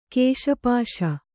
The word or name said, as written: ಕೇಶಪಾಶ